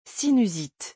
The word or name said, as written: sinusite